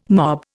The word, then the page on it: mob: Indian